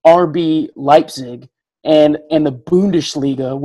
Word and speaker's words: Bundesliga: Amazingly, you, I don't even think you know what you just did, but you said Bundesliga. Like, I'm pretty darn sure that's how you say it in Deutsch. Like, we say the Bundesliga here in the United States, but I think you had that little H at the end, the Bundesliga.